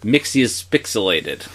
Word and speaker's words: Mxyzpixilated: We are, of course, talking about Mr. Mxyzpixilated's debut in the aptly titled episode, Mxyzpixilated. – There you go. Great pronunciation there.